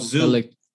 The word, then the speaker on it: Zhu: Z-H-U. – Oh, Zoo. – Yes. Is it Zoo or Shu? – I heard it's Zoo.